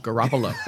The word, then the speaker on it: Garoppolo: Garoppolo. let me give you a pronouncer on that one.